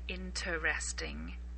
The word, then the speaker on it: interesting: Now, note that with the word interesting, most native speakers will not pronounce the first E. We very rarely say interesting. It's more like interesting. It's as if the first E didn't exist.